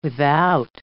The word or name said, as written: without